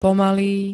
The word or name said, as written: pomalý